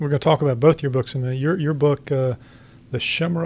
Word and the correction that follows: Chimera: of Prague, did I pronounce that right? – It's Chimera. – See, I can't even get that right. – It's from the Greek.